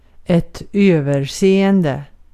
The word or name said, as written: överseende